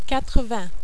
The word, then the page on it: 80: French